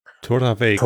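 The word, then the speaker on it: Torabhaig: Tora Vague, also so ein BH spricht man wie ein V oder wie ein W. – Torraweg.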